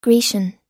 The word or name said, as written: grecian